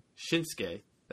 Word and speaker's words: Shinsuke: Kaelin says you need to stop pronouncing Shinsuke like Shitstein. – Shinsuke. – Shinsuke Nakamura. – How is that anything like Shitstein? Shinsuke. That's how you pronounce it.